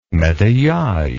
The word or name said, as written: metayage